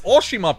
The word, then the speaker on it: Oshima: It's Oshima, people.